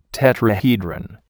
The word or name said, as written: tetrahedron